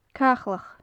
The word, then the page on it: caclach: gaelic